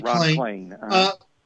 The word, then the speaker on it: Klain: But since you worked at the White House with the new chief of staff, Ron Klain, is am I pronouncing his name right? – You are. That's exactly right, Ron Klain.